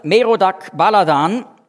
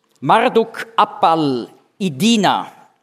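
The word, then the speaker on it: Marduk-apla-iddina: Dieser Merodach Baladan, oder babylonisch ausgesprochen, habe ich das erklärt auf Seite 1 ganz am Schluss, Marduk Appal-Idina. Dieser Marduk Appal-Idina, oder hebräisch ausgesprochen, Merodach Baladan, wird ausdrücklich in der Bibel erwähnt.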